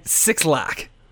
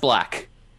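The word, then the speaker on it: 6lack: The Pink Phantom by the Gorillaz featuring Elton John and Sixlack. – It's actually pronounced Black.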